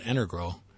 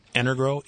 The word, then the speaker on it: Energro: So, yes, we experimented and have developed a product called Energrow that is actually that product that you saw. – What spell that? – Energro, E-N-E-R-G-R-O. – Okay, Energro.